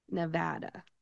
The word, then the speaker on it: Nevada: When you say this state name, you are going to say Nevada. Nevada.